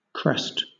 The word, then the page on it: crest: British English